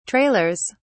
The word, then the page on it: trailers: British English